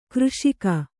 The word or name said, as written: ಕೃಷಿಗೈ